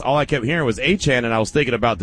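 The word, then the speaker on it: Etienne: So I would have, Harves, I would have said ETN. Just, I'm keeping it a buck. I just know that how you say it is Achan.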